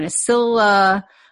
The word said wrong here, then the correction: Oscillococcinum: I'd say oscillococcinum. – Oscillococcinum. Okay, I was way off.